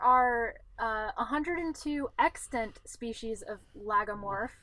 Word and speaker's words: lagomorph: So all my life, I thought that they were in the category of logomorph. And as it turns out, I'd been saying that wrong the entire time I've ever existed. And it's actually lagomorph.